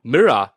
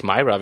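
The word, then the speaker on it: Meera: Mirror, wie sie übrigens ausgesprochen wird, also wie das englische Spiegel und nicht Myra.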